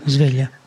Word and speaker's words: Sveglia: Is that the right pronunciation? Okay. – Sveglia. – Yeah.